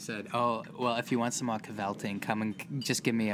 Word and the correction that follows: kvelling: It's pronounced kvelling.